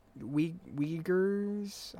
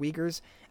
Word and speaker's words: Uyghurs: And we're already reliving history with these concentration camps in China, killing Muslims, and I believe it's pronounced Uyghurs. I believe that's how it's pronounced. Uyghurs.